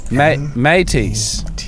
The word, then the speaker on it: Métis: Well, I love the Métis thing. Am I saying that right? – What are you talking about? – Métis? M-E-T-I-S. Métis.